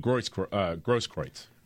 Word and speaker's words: Grosskreutz: Grosskreutz? – Oh, Grosskreutz.